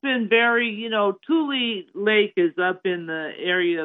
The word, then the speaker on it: Tule Lake: Tule Lake, yes.